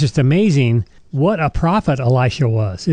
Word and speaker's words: Elisha: So a lot of lectors will say Elisha, which that way you can at least differentiate between Elijah and Elisha. But yeah. It's pronounced Elisha. That's how it's pronounced.